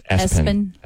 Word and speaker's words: ESPN: Pronounced Espen.